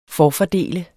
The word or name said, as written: forfordele